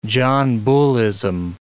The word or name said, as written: John Bullism